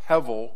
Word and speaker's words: hevel: The Hebrew word is pronounced hevel, and it's the idea of something that you can see but you can't grab.